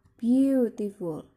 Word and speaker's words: beautiful: Kemudian beautiful. Di sini ada tiga suku kata atau tiga syllable, yaitu beautiful. Ya, yang ditekankan di sini yaitu yang di awal, beautiful.